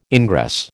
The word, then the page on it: ingress: American English